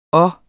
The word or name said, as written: og